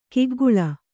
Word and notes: ಕಿಗ್ಗಳ: kiggaḷa

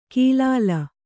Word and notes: ಕೀಲಾಲ: kīlāla

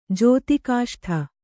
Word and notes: ಜೋತಿಕಾಷ್ಠ: Kannada

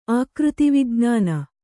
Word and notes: ಆಕೃತಿವಿಜ್ಞಾನ: ākřti vijñāna

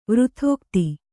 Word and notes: ವೃಥೋಕ್ತಿ: kannada

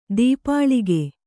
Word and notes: ತಪೋಲೋಕ: tapōlōka

